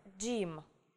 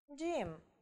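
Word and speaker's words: gym: Буква G будет читаться как звук D. Ну, например, Джим. Джим. Или Пейдж. Пейдж. Вот посмотрите, да? То есть у нас тут буква G стоит перед гласной Y, поэтому она читается как Джим, да?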